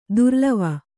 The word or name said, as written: ದುರ್ಲವ